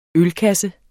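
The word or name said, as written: ØLKASSE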